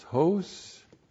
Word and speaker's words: hos: That conjunction of comparison is hos, H long O-S.